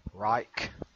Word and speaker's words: Reich: Fourth Reich or Third Reich? The third was World War II, I believe, so Fourth Reich. Is Reich even the right word? – Yep, Reich.